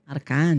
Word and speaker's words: Arcangeli: Arcangeli. Eu pronunciei certo, então, Arcangeli. Arcangeli. Tem gente que fala Arcangeli. Não, Arcangeli. Arcangeli.